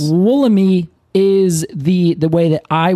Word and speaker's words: Wollemi: I'd say, oh, yeah, Wollemi. However, in the place where this is from, they would pronounce it the Wollemi Wilderness. So the tree is named after the wilderness, which they pronounce Wollemi.